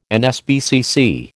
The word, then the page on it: NSPCC: American English